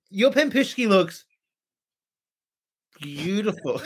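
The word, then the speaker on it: Pampushki (пампушки): Well, I paused because I was like, pampushki, right? Did I say it right? – Pampushki, yeah.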